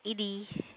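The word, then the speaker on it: Edi: My name is Edie. – Okay, how do you spell that? – It's E-D-I.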